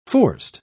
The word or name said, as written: forced